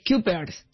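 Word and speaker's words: Cuypers: Es C-U-Y. – Kuipers.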